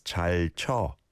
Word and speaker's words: 잘 춰: Yeah, if you look at the 춰 syllable, it is an oo, oo sound, so wa, chwa, chwa, but I don't think you overly pronounce that right.